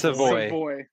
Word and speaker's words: Savoy: I have them going Matthew Soive. I'm sure nope. – I butchered that Savoy. Name. – It's Savoy.